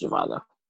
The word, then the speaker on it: Zhivago: Yeah, it's pronounced Zhivago.